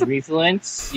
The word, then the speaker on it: Refluence: My name is Refluence. You can pronounce it the way you pronounce influence, so like reverse.